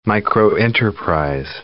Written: microenterprise

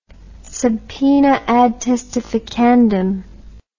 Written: subpoena ad testificandum

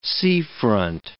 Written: seafront

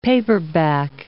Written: paperback

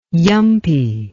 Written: yumpie

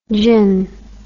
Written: gyn

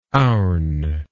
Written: ourn

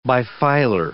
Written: bifilar

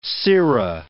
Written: sera

